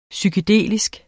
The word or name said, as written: psykedelisk